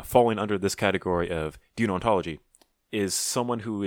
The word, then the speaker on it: deontology: Deontology? – Deontology.